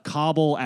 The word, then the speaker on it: Kabul: I don't care if you're listening in New York City, Chicago, LA, Tucson, Arizona, Kabul, Afghanistan, which you'll find out that I learned is how you pronounce that on this episode.